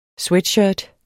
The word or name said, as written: sweatshirt